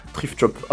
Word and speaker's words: Thrift Shop: Thrift Shop, à peu près.